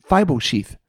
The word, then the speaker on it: Mephibosheth: Muff, muff, Mephibosheth.